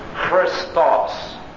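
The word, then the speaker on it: Christos (Χριστός): And the accent on the second syllable is pronounced Christos. Christos.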